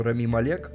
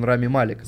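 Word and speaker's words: Rami Malek: обрадовались, я наконец-то прочитал, как правильно произносится. Я думал, что у него французские корни, и говорил Рами Малек. У него египетские корни, он Рами Малик.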